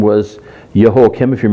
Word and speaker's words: Jehoiakim: And his name is pronounced Jehoiakim.